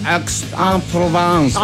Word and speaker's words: Aix-en-Provence: Oye, también ganó Tavilo, se coronó sí, campeón del Aix-en-Provence, así me dijo Caíto que se pronunciaba.